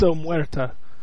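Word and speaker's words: omertà: It's Omerta.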